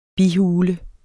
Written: bihule

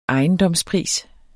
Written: ejendomspris

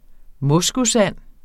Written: moskusand

